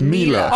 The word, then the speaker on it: Miele: M-E-L-E-E, like Miele. – Mele. – It's pronounced Miele. – Oh, it's Miele, isn't it? Miele, isn't it? M-I-E-L-E.